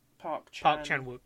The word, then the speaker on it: Park Chan-wook: And I've seen this obviously, which is Park Chan-wook. I think that might be wrong. Old Boy, 2003. – No, you're right. – Was I getting his name right? – Park Chan-wook, yeah. Chan-wook.